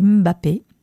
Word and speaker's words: Mbappé: Et puis là, notre Kylian Mbappé. Non, c'est pas comme ça, c'est Mbappé, la prononciation.